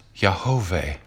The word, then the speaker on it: Yahweh: The four Hebrew letters that make up the Father's name, YHVH, Yud-He, Vav-He, pronounced Yahweh, literally means will, being, was, or who was, who is, and who will be.